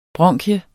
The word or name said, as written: bronkie